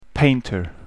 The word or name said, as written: painter